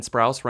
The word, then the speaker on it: Sprouse: And Sprouse rhymes with spouse.